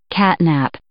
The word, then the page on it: catnap: noun